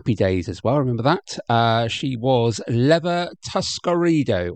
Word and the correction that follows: Tuscadero: or Tuscadero. There we go. Get it pronounced correctly.